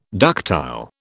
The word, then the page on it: ductile: British English